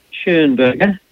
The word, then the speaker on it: Schönberger: Yes, how do you pronounce that? – We call it Schönberger. – Schönberger. – It has an umlaut over the O. So it makes it a Schönberger.